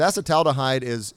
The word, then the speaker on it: acetaldehyde: One thing about this that actually is one of the better benefits of it is it keeps the blood concentration of acetaldehyde. I believe is how you say it. Keeps it extremely low. And it's acetaldehyde.